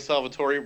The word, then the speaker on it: Salvatore: Did you work with I don't know if I get his name right, but Salvatore, did you work with him or did you work with someone else? – Yeah, I mean, so it's R.A. Salvatore Robert, but we always called him Bob.